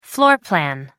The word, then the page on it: floor plan: British English